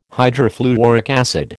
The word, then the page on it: hydrofluoric acid: American English